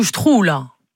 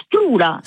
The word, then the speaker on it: sprula: Questo strula? – No, strula. S-P-R-U-L-A. – Strula. – Ma conoscete mica questa parola? No, è un bello strula questo.